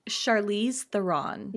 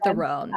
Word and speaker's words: Charlize Theron: I think it's Charlize Theron. – Yeah. – Is how you say it. Or yeah, Theron.